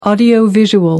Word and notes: audiovisual: American English

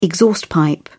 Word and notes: exhaust pipe: British English

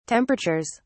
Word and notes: temperatures: British English

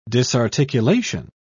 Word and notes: disarticulation: British English

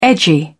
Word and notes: edgy: British English

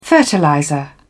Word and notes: fertilizer: British English

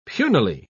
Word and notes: punily: British English

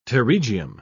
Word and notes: pterygium: British English

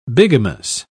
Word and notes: bigamous: British English

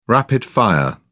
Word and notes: rapid fire: British English

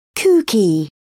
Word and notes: kooky: British English

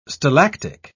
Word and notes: stalactic: British English

